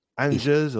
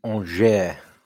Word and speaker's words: Angers: Is it a silent G? Angers or? – It's Angers. There you go. – Angers. – Angers, like the steakhouse.